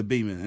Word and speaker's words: Beaman: The Beeman. And it's spelled like you would spell Beatrice. B-E-A-M-A-N. – Beeman? – Yeah.